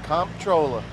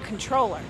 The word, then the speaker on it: comptroller: There are times when I just say controller without the P.